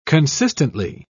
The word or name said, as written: consistently